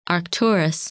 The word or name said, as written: Arcturus